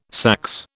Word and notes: saxe: British English

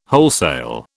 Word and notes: wholesale: British English

